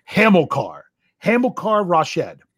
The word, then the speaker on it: Hamilcar Rashed: Oh, I keep messing up the middle syllable of his name, so I'm going to try to get it right. I'm pretty sure it's Hamilcar Rashed.